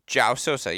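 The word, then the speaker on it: João Sousa: Joao Sousa.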